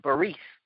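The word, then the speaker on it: berith (בְּרִית): And the Hebrew word for covenant is pronounced berith.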